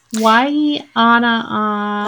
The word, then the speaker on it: Waianapanapana: Is it the Waianapanapana? – Yes, that's okay. Yes. – Waianapanapana. – Like Napanapa.